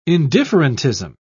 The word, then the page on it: indifferentism: British English